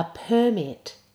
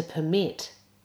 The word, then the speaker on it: permit: So this is the noun, a permit, as opposed to the verb, which is to permit. Try it again with a good er sound. The noun, a permit. Expert.